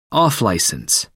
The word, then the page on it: off-licence: American English